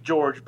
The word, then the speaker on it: Jorge: And it's actually George, but it's spelled Jorge.